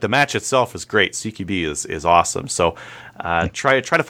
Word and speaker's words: CQB: How do you say the CQB one? I'm always confused. – It is service CQB.